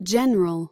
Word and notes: general: American English

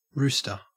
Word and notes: rooster: Australian English

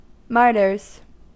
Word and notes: martyrs: American English

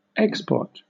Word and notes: export: British English

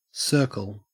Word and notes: circle: Australian English

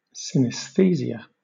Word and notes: synaesthesia: British English

